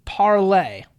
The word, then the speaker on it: Parler: Parler, or maybe Parlay. I'm going to call it Parler, but I have heard it pronounced both ways.